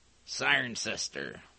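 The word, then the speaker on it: Cirencester: It's C-I-R-E-N C-E-S-T-E-R. Siren sister. – Siren sister.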